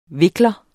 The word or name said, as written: vikler